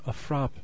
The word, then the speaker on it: frappe: In parts of Canada and New England, such as Boston, Massachusetts, milkshakes are known as a frap. A frap. A frap? F-R-A-P-P-E? The E is silent.